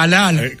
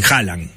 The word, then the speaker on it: Haaland: ¿Cómo lo pronuncias vos? – Yo digo Haaland. – Haaland, perfecto. Haaland. Pero lleva más de una, ¿eh? – Lleva dos, así. – No quiero ponerme en esquina. No, hay que ver la pronunciación noruega, que yo no la tengo.